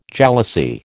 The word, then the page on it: jalousie: American English